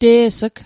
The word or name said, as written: te's'g